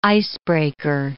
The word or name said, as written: icebreaker